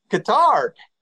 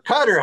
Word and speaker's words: Qatar: And you look at Qatar or Qatar, however you want to pronounce it now. It used to be Qatar, now it's Qatar.